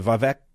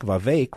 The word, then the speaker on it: Vivek: And I think Nikki Haley diminished herself with her insult to Vivek Ramaswamy, rhymes with lake, Vivek Ramaswamy.